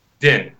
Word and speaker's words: Dinh: Dinh, that's right.